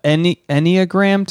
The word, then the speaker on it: enneagram: We just took the enneagram test.